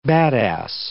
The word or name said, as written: badass